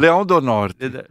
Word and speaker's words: Leão do Norte: Leão de Norie. No. – Leão do Norte.